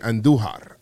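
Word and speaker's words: Andújar: Roll your R's when you say Andujar. I think he's going yard today. Miguel Andujar.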